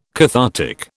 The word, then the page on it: cathartic: British English